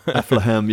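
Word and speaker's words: Affligem: But this is Aflehem, I think. Is that how you say it? Aflehem? – Aflehem, yeah. – Aflehem, yeah. – Spelled with a G, but it's, brewery. I guess it's a Dutch pronunciation.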